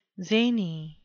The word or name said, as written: zany